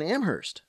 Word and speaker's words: Amherst: This is Amherst, Mass. – Hey, how are things in Amherst? – It's actually Amherst, Mass. – Oh, excuse me. – No, that's okay. Only the H is silent in Amherst.